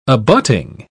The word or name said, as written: abutting